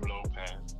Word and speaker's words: Lopez: The name is spelled K E A N a N, last name Lopez, L O P E Z.